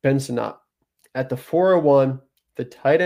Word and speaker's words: Sinnott: I believe it's Sinnott. Sinnott is how I heard the announcer saying it when I was watching.